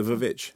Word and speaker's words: Vvitch: How did you say it? – The Vvitch. – You do say it. Like that. – Yeah, it's like Fantastic. It's to do with the way that it is styled on the poster.